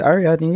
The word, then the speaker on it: Ariadne: Ariadne. – Yes. Is that what you're doing? Yeah, Ariadne? that's good. Ariadne.